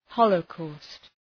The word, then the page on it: holocaust: ‘həʋlə,kɔ:st, ‘hɒlə,kɔ:st